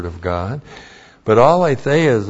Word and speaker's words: aletheia: And then, aletheia. A-L, long E-T-H-E-I-A, aletheia. And aletheia is the Greek word for absolute truth.